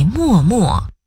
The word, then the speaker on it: 脉脉: 墨只用在默默，默默是形容词，形容含情凝视或用眼神表达情意的样子。